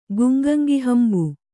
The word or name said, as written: ಗುಂಗಂಗಿಹಂಬು